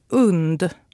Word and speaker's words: UND: I rapport på SVT sa de UND. Vi på radion har blivit informerade om att det ska uttalas UND. Tänk hund minus H-et.